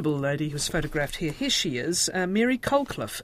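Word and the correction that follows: Colclough: is it? – Pronounced Coakley, yeah, Coakley.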